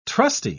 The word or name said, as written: trusty